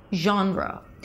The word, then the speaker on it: genre: Now, this word is derived from French. Now, in French, you pronounce this word as one syllable, something like this. Genre. Genre. Sorry, French speakers. I'm not a French speaker, so I guess it's somewhat close, but it's not like how you pronounce it in American English, although it's the same spelling.